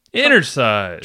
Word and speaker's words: Inner Size: Oh, I have to do a quick edit of that, because I was like, wait, hold on, did I mispronounce that thing? That would be a bad thing on the internet. – We don't need to edit that. You said it right. – Inner Size.